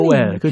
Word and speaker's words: Joel: 조엘.